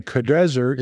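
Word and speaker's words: Nebuchadrezzar: again, Kedrezer is probably the proper pronunciation, and your transliteration probably has it that way.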